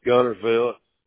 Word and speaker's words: Guntersville: And I see that the business address is there in Guntersville. Am I saying that correctly? – Yes, Guntersville. Yes, sir.